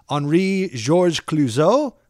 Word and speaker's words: Henri-Georges Clouzot: From 1955, this is Henri-Georges Clouzot.